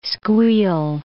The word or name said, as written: squeal